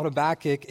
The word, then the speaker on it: Habakkuk: Now, you might think that I'm saying it wrong, but in fact, no one really knows how to pronounce Habakkuk, so you can say it however you want, and you can be right. But I call it Habakkuk.